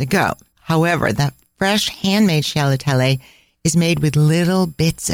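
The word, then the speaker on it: scialatielli: It's a bit of a tongue twister to say. So if you just say it faster, it might sound better. Sciallatielli. Sciallatielli.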